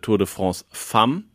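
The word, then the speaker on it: Tour de France Femmes: Ich habe mich von unserem französischen Native Speaker im Team Clemens mir abholen lassen, dass ich das letzte Mal falsch gesagt habe. Aber es heißt Tour de France Femme.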